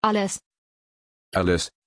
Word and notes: Aless: German

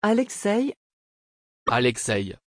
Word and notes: Aleksej: French

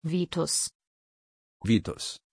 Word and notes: vitus: German

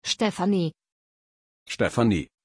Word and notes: Stefani: German

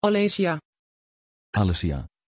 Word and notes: Alesya: Dutch

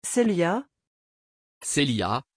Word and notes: celia: French

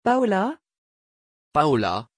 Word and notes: paola: French

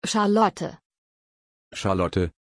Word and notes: charlotte: German

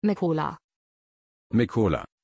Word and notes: Mykola: German

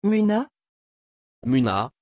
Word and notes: Muna: French

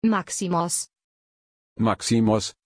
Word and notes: Maximos: German

